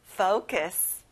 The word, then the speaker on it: focus: 자, 포커스가 아니라 앞에 fo 소리가 나요. focus.